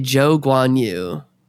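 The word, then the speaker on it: Zhou Guanyu: Oh, Zhou Guan Yu. – How do you pronounce his first name? I never say his first name because I'm always so scared. – It's... The commentators say Zhou Guan Yu, where when he was racing in F2, he was Guan Yu Zhou.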